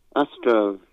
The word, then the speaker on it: Astro: Astro. Astro? Yeah. Like AstroTurf? Yeah.